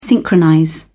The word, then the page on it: synchronize: British English